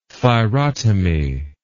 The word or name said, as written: thyrotomy